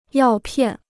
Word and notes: 药片: yào piàn

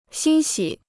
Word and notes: 欣喜: xīn xǐ

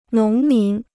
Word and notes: 农民: nóng mín

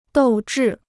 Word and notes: 斗志: dòu zhì

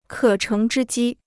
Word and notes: 可乘之机: kě chéng zhī jī

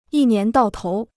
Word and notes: 一年到头: yī nián dào tóu

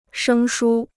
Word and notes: 生疏: shēng shū

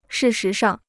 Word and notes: 事实上: shì shí shàng

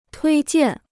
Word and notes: 推荐: tuī jiàn

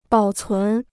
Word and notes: 保存: bǎo cún

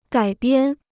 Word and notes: 改编: gǎi biān

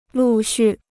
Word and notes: 陆续: lù xù

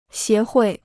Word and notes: 协会: xié huì

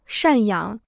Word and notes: 赡养: shàn yǎng

